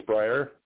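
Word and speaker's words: Briare: And did I slay your last name when I say it's Breyer? – you are one of the few people that have actually pronounced it correctly. It was Breyer, just like the ice cream.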